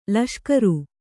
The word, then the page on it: ಲಷ್ಕರು: laṣkaru